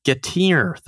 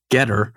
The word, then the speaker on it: Getir: Moving along, Getir, the Turkish super-fast grocery deliverer that many Americans actually pronounce Getter, acquired its German competitor, Gorillas.